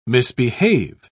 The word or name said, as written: misbehave